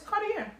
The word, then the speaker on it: Cartier: Am I saying it right? Cartier? – It's Cartier. Yeah, that's right.